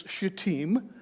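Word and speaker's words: shittim: But the actual pronunciation is pretty easy. It's shatim. If you were to say it singular, it would be shata.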